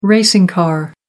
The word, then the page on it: racing car: American English